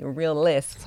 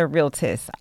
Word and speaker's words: Realtists: Thank you for saying the name correctly, right? We are the Greater Toledo Realtis Association. People call and say realists so many times. – Yes. – But we are the realtists.